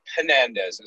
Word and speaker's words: Fernandes: I think it's pronounced Fernandes as well.